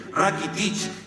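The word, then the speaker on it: Rakitić: Después tenés los croatas y otra vez el mundial, que terminan en ic, aunque ellos pronuncian ich, Lucas Modric, los futboleros, Rakitic, hijo de un hombre que no tiene que haber sido muy gordo si se llamaba Rakitic, los armenios.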